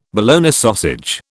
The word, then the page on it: Bologna sausage: British English